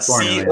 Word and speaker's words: Coronel: How do you say it? Coronel? – Yeah. Coronel. C-O-R-O-N-E-L.